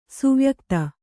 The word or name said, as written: ಸುವ್ಯಕುತ